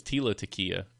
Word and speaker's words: Tila Tequila: Is it Tina Tequila or Tila Tequila? – I thought it was Tila Tequila.